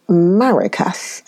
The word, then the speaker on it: maracas: It is also used in some countries, such as Colombia, to make maracas, M-A-R-A-C-A-S, maracas, that's the right pronunciation, maracas.